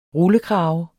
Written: rullekrave

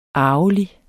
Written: arvelig

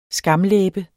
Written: skamlæbe

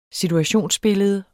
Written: situationsbillede

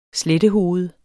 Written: slettehoved

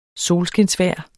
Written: solskinsvejr

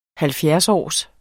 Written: halvfjerdsårs

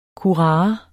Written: kurare

